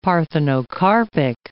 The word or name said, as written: parthenocarpic